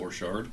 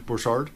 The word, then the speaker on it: Borchardt: Borchardt. – Borchardt, yeah.